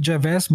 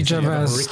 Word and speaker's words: Gervais: Unabhängig vom Preis ist das Opening immer das, das wirklich satirischste und bissigste und böseste, wenn Rick Ricky Gervais meist die Golden Globes äh, moderiert.